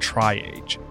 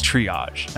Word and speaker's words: triage: She called me after listening to the podcast and let me know that I had mispronounced triage. I called it triage. So it's triage, not triage.